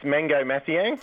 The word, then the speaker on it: Mangok Mathiang: So your first all-Aussie team would be Drimmick, Hooley, yep, Joyce, Ben Simmons, who we've spoken about, and Mangok Mathiang. How do you pronounce that? – I'll help you out there. It's Mango Mathiang.